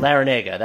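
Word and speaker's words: Larrañaga: Spurs assistant James Borrego, Jerry Stackhouse, head coach of the Toronto Raptors G League affiliate, Celtics assistant Jay Laranga. I can't pronounce his name. Laranga? Man, that's bad. – Is it Laranega? I haven't seen that name. – Laranega, that sounds right.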